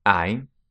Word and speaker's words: hai: Literally, hai. – You have, spelt H-A-I, but the H is silent. The H is always silent in Italian.